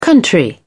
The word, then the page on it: country: American English